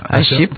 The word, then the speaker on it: AHEP: C'est comme ça que vous prononcez le nom? – AHEP. – AHEP. Oui, voilà.